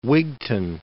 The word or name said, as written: Wigtown